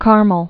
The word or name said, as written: Mount Carmel